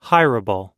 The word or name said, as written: hireable